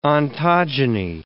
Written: ontogeny

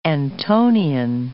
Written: Antonian

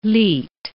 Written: lied

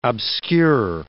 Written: obscure

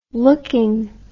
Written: looking

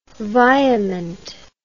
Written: virement

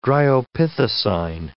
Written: dryopithecine

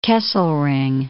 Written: Kesselring

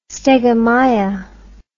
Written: stegomyia

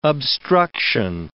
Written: obstruction

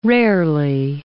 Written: rarely

